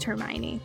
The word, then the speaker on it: Hermione: Whatever it was, not anything would have prepared me to learn that it's pronounced Hermione because I was like, those letters don't go together that way.